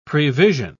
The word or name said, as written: prevision